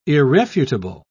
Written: irrefutable

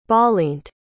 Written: balint

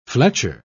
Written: fletcher

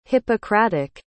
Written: hippocratic

